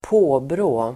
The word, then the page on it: påbrå: ²p'å:brå: